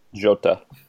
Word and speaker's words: Jota: Then Diogo Jota that's definitely not how you pronounce it. – Jota. – Is it? See, my Portuguese isn't up to scratch, I told you. – Yeah, yeah. Portuguese is easy. You think of Jose Mourinho, you actually pronounce the J.